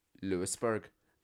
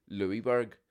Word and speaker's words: Lewisburg: There's a place called Lewisburg. But the Lewis is spelled as Louis. So I always call it Louisburg because it is spelled as Louis, L-O-U-I-S. But they pronounce it Lewisburg. And that my manager in a previous employment was from Louisburg. But she says, no, it's not Louisburg, it's Lewisburg.